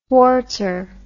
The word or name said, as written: swartzer